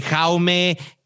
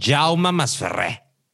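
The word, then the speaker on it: Jaume Masferrer: Sí, todos que Jaume, que Jaume, no, Jauma más Ferré.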